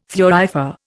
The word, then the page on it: thurifer: Indian